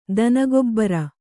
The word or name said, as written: ದನಗೊಬ್ಬರ